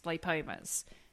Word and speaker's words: lipoma: And it was all basically, it was either cysts or they were lipomas. Lipomas. Is that how you pronounce it, Kate? – Yeah, lipoma, yep.